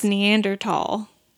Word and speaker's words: Neanderthal: Are you not supposed to pronounce the th? – Apparently it's Neanderthal. Yeah. – Since when? – I don't know. – Forever, apparently.